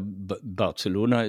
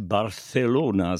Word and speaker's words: Barcelona: Barcelona ist laut Aussprache Duden des Deutschen die korrekte deutsche Aussprache dieser Stadt. Man kann auch natürlich auf Spanisch Barcelona sagen mit dem stimmlosen TH, aber auf Deutsch kann man Barcelona sagen.